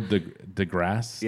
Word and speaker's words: deGrasse: Is it Neil deGrasse? – Yeah. – I'm always afraid to say that his middle name wrong. – You just leave it out? – Because I'm thinking Degrassi.